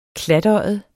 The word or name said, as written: klatøjet